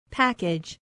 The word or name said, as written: package